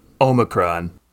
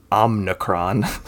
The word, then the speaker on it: Omicron: The second, how do you pronounce, of course, is Omicron. – Hasselhoff? – Or should we say, as Joe Biden recently said, Omnicron? – I mean, it's a simple mistake to make.